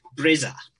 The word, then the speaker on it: Brezza: Brezza. Did I say that right? Welcome. – Hi. Yes, you did. Brezza.